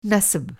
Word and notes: नसब: نسب

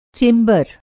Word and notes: चैंबर: Hindi

